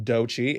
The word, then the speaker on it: Doechii: While we're talking about artists, I want to talk about Doechii. If you're not familiar with Doechii, it's spelled D-O-E-C-H-I-I.